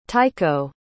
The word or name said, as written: tycho